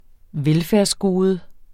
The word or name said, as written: velfærdsgode